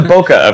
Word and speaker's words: Broca: Like, so they're working on the Broca. Is that it? Am I saying it right? – Yeah. – Is it Chewbacca or Chewbacca? – It's the Broca effect.